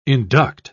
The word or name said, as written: induct